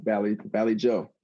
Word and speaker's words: Vallejo: Vallejo, California. – Yes, the Valley Joe.